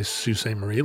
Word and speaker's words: Sault Ste. Marie: Well, I would say Sault Ste. Marie.